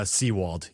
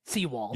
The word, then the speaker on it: Sewald: It is uh Seawald. You were there the first. Well, I guess your second time. – Seawald.